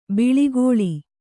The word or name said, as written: ಬಳಿಗೊಳ್